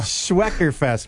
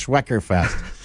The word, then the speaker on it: Schweckerfest: The Schweckerfest, my bad. It's not Schwertel Fest. It's Schweckerfest. – Because it's in line with the Eckers Apple Farm. – Because it rhymes with Eckers.